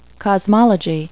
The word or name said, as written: cosmology